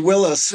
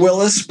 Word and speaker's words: Willis: So it's actually Willis. We actually pronounce it Willis, but okay. My ancestors were poor and didn't know how to spell.